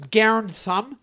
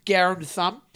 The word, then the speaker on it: Garand Thumb: is Garen Thumb, which is spelled, like, it's like G-A-R-A-N-D space thumb, like as in thumb and fingers, you know, Garen Thumb.